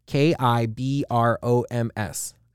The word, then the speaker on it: Kibroms: K I B R O M S.